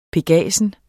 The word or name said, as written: pegasus